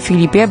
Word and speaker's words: Philippe: Wypowiada się to tak samo. Filip w dwóch językach. Moi rodzice zresztą wybrali takie imię, żeby to brzmiało tak samo.